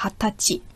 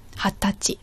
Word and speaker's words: Hatachi: Und wichtig ist auch, dass es gibt eine Ausnahme von der Aussprache her. Und zwar 20 Jahre alt, sagt man Hatachi. Hatachi.